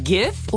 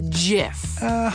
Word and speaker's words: GIF: Wait, GIF or JIF? – Hard G.